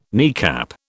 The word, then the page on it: kneecap: British English